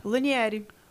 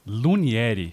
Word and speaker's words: Lunieri: Me explica primeiro o seu nome, como é que se pronuncia? Lunieri. Lunieri. – Isso. É francês? – É francês. E francês seria Lumière, né? – Ah, é?